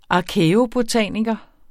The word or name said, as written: arkæobotaniker